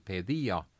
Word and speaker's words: paideia: ütleme paideia, paideia on, on termin kreeka keeles hääldatakse pedia, see on äh, selline eliitharidus.